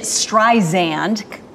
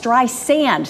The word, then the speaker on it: Streisand: Yeah, so Siri pronounced it Streisand, more with a Z sound, and she's like, no, it's Streisand, sand, like the beach.